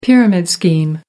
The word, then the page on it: pyramid scheme: American English